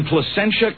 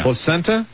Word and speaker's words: Placentia: The police chief in Placentia, California. – Placenta? – It's spelled like placenta. – Placenta, California. – With an I. So I guess it's Placentia.